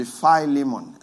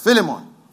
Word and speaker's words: Philemon: If you don't know how to pronounce it, it would be Philemon. It's Philemon. Philemon.